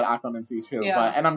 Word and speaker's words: occlumency: And there is an accent on the loo, so Dan would technically be correct, but I'm going to still say occlumency because I like it that way.